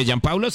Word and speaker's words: Gianpaolo: Bueno, se escribe Gianpaolo, se lee Gianpaolo.